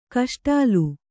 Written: ಕೇಸೊಡಲ

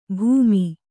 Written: ಭೂಮಿ

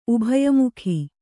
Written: ಉಭಯಮುಖಿ

